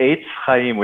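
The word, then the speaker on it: Etz Chaim: The first time around was correct. Eitz Chaim, which actually means a tree of life.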